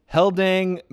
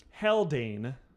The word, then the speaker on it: Haldane: Heldang is not it? – Heldang. H-A-L-D-A-N-E.